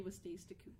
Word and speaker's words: Wastasecoot: AJ Wastasecoot.